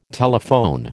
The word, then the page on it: telephone: American English